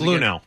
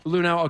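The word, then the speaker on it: Luhnow: It says, on September 22nd, 2016, an intern in the Houston Astros organization showed General Manager Jeff, is it Lunau? How do you pronounce it? – Lunau. – Lunau, okay.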